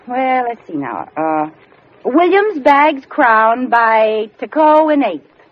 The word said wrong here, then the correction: TKO: That's TKO. – Well, how would you pronounce it? – Technical knockout.